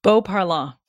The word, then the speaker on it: Beauparlant: Beauparlant.